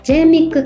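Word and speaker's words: J-MICC: まずお聞きの皆様にお伝えしたいのが、このプロジェクトは正式名称を日本多施設共同広報と研究といって、略称は JMIC 研究、スペルは JMICC と書いて JMIC 研究というふうに呼ばれています。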